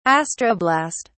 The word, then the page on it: astroblast: British English